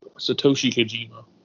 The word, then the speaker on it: Satoshi Kojima: Say maybe you can pronounce it a lot better than I can. You're better with this. – Satoshi Kojima. – There we go.